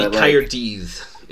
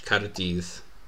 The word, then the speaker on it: Caerdydd: It'd be Caerdydd, is how you say it in Welsh. Caerdydd.